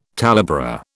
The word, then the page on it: talebearer: British English